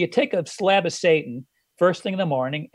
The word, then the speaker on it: seitan: actually called it's, it's spelled S E I T A N with a thingy over the A. So, so they call it Satan.